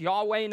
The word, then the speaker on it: Yahweh: Tom Wright points out that the ancient Israelites, when they came to those consonants, YHWH, that we pronounce Yahweh now, would say instead Adonai.